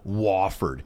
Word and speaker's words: Wofford: I'm going Wofford.